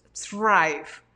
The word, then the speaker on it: Thrive: De blooming, de, crecer, de TH, T-H-R-I-B chica E, Thrive. Es que lo pronuncié como Drive, porque la TH como que es como, pero es Thrive Architect.